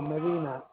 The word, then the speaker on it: Medina: No, Medina, M-E-D-I-N-A. – I'm sorry, spell it for me one more time. – M-E-D-I-N-A.